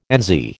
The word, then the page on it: NZ: American English